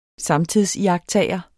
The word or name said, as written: samtidsiagttager